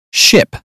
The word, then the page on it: ship: British English